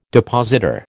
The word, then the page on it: depositor: American English